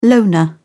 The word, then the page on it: loner: British English